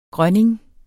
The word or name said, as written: grønning